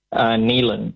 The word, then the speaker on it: Neilen: Neilan here. Can I have South Africa, please? – Yeah, of course you can. Are you, are you clear? Is your name pronounced Nevan or Neilan? – Neilan. N-E-I-L-E-N. – N-E-A-L-E-N.